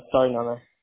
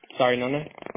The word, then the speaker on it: Sarinana: Sorry, Nona. – Excuse me? – Sorry, Nona. – How do you spell that? – S-A-R-I-N-A-N-A.